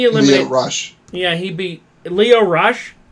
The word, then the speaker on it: Lio Rush: It's it's Lio Rush.